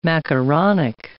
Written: macaronic

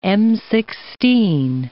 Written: M16

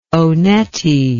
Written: Onetti

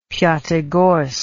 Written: piatigorsk

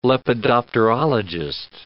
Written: lepidopterologist

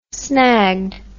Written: snagged

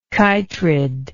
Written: chytrid

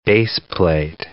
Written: baseplate

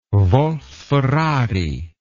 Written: wolf ferrari

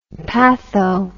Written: patho

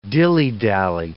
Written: dillydally

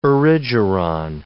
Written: erigeron

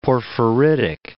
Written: porphyritic

